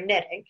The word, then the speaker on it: knitting: And it's like, "Yeah, because it's a silent K, like you're not knitting, you're knitting."